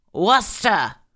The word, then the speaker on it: Worcester: A good rule of thumb for pronouncing Massachusetts town names is to drop all the R's in them and slur them like you're shit-faced. Worcester!